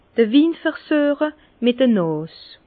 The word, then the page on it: d'r Win versüeche mit 'd'Nàs: Bas-Rhin; Herrlisheim